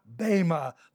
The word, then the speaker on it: Bema: Verse 10, "For notice, we," Paul includes himself here, "we must all appear before," there it is, Bema, the Greek word again, B-E, pronounced as a long A, Bema, the judgment seat.